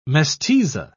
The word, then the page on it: mestiza: British English